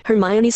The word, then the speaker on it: Hermione: H-E-R-M. Found. Read only, edit. Edit, read only. Blank. Hermione's Secret 386.